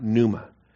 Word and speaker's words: pneuma: Pneuma, P-N-E-U-M-A, pneuma, silent P on the pneuma.